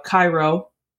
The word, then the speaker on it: Kairo: Uh, Kairo um, is the Japanese pronunciation of um, the film, and the Americanized title is Pulse.